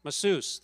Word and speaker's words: masseuse: How do you say the person who gives you massage? Masseuse. Thank you.